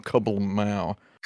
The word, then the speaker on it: KBLMAO: It's hard to pronounce, but it's written KBLMAO. Kablamao.